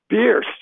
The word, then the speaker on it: Birss: which I think is Briss. Is that, am I pronouncing it right? Is that correct? – No, you've swapped the R and the I. It's Birss. – Birss.